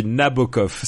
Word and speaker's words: Nabokov: On dit Nabokov, s'il te plaît.